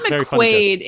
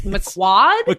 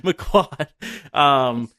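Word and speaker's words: McQuoid: It's one syllable. It's two syllables in her last name. – Yeah, it's not McQuaid. It's McQuad. – McQuad.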